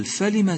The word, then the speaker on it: فلم: أيضا لاحظ في قوله تعالى قل فلم تقتلون أنبياء الله، فلم لا تقل فلم، أي لا تشبع الفتحة في النطق حتى لا تتحول إلى ألف، قل فلم وليس فلم، فالميم مفتوحة ولا يوجد بعدها حرف ألف كما تلاحظ.